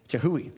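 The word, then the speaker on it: Chihuly: Chihili and others. In the past year, did I say it wrong? Chihui. Thank you.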